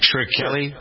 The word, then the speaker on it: Shkreli: That title now belongs to Martin Shkreli, I believe is how to pronounce the name, isn't it, Kenny? S-H-K-R-E-L-I. Shkreli. Shkreli.